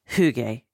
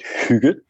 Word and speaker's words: hygge: So yeah, I've, I've, I've heard many variations, but in, in Denmark we pronounce it hygge, and it is essentially the art of creating a nice atmosphere.